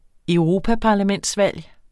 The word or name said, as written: europaparlamentsvalg